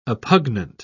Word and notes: oppugnant: British English

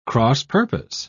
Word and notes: cross-purpose: British English

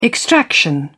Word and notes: extraction: British English

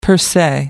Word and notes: per se: American English

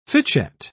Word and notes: fitchet: British English

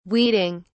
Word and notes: weeding: British English

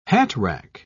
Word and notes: hatrack: British English

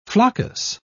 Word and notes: floccus: British English

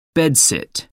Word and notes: bedsit: American English

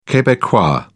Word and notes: quebecois: British English

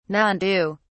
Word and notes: nandu: British English

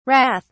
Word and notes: rath: British English

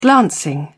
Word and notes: glancing: British English

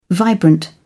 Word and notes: vibrant: British English

